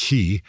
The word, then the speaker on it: Xi: By the way, I want to make it very clear that the head of China, President Xi, probably better pronounce that Xi, that he told President Biden in San Francisco late last year that China would act to retake Taiwan.